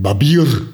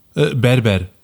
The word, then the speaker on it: Berber: Es gab bei der Moschee auf der Venloer Straße gab es ein Barbier. Oder Barbier, sagt man, ne? Habe ich das gut ausgesprochen? – Also, du meinst das türkische Wort? – Ja. – Berber. – Berber. Ja, genau, das war's.